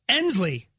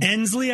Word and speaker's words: Ensley: And this one, how'd you pronounce this one? – Ensley, I think. E-N-S-L-E-Y.